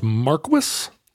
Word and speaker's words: Marquess: It's pronounced Marquess. – I'm going to say, yeah. – Wow. – I mean, I learned so much from you. – Well, Marquis would be the French title.